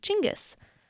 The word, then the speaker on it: Chinggis: Side note, most of the world pronounces Genghis as Chinggis, but his birth name was actually Temujin.